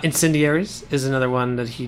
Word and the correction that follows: Incendies: Incendies, yeah, sorry, yeah. I'm adding vowels, I'm adding all the syllables that aren't there.